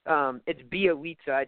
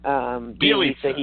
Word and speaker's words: Bielitsa: Just before you go to the call lines, I just want to say, just like I kind of I butchered who the announcer is, it's Bielitsa. It's such a weird pronunciation. I don't know why it's that way, but that is the proper pronunciation for Bielitsa.